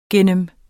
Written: gennem

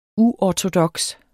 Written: uortodoks

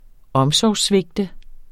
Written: omsorgssvigte

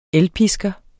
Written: ELPISKEREN

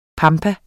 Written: PAMPAERNE

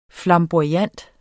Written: FLAMBOYANT

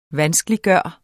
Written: VANSKELIGGJORT

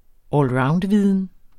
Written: allroundviden

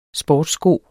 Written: SPORTSSKO

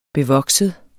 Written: bevokset

